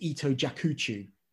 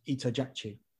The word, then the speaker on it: Ito Jakuchu: Well, if you're English, you'd read it as Ito Jakuchu, but in Japanese, it's pronounced Ito Jakchu.